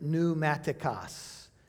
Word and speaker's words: pneumatikos: It is the Greek word pneumatikos. It's spelled P-N-E-U-M-A-T-I-K-O-S.